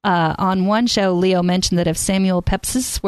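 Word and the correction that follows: Pepys: were alive today. – Peeps. – I knew I'd pronounce that wrong. Pepsis. – I never knew how to pronounce Peeps. – It. I may still be saying it wrong, actually.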